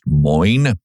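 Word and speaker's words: Moyn: They published an article by a professor who is from Yale, Samuel M-O-Y-N, I believe it's pronounced Moyne.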